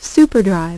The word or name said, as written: SuperDrive